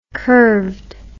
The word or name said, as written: curvedness